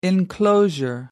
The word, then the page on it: enclosure: American English